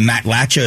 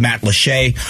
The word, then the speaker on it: Matlacha: It's spelled Matlacha. If you look in the news, people are calling it Matlacha. It's pronounced Matlache.